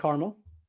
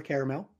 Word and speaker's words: caramel: Three people said caramel or caramel, whichever way you pronounce it. Either way is fine.